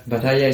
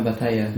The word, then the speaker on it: Batalla: Aber ja, Batalla ist mein Nachname. – Ah, Batalla? – Ja, Batalla. – Wie Paella. – Ja, genau. LL mit meinem Gil ausgesprochen.